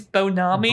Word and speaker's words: Bon Ami: I'm actually stuck on this because, yeah, the cleaner brand with the slogan hasn't scratched yet is Bon Ami. – I don't even know where the space is there. Maybe it's Bonami. – Bonami? It rhymes with Konami. I think it's Bon Ami, right?